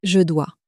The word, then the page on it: je dois: French